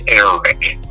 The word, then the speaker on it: Eric: Um, you know, my name is Eric. they don't say Eric. They cannot pronounce the R that way they want to roll the R and they want it to be attic.